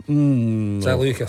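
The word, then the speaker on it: Žaliūkas: The late, great Marius Zaliuska. – Zaliuskas. – I'll let you away with the mispronunciation. Zaliuskas.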